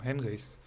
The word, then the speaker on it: Henrichs: Ähm, und äh, Benjamin Henry, Heinrichs, Henrichs, so rum, Henrichs.